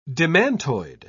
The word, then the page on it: demantoid: British English